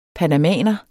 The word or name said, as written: panamaner